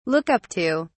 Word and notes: look up to: British English

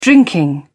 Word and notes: drinking: British English

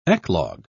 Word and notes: eclogue: British English

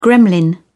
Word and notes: gremlin: British English

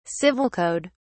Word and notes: civil code: British English

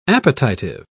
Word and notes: appetitive: British English

